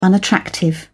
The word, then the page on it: unattractive: British English